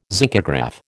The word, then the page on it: zincograph: American English